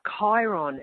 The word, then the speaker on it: Chiron: I get lots of questions about Chiron, and Chiron is spelt C-H-I-R-O-N.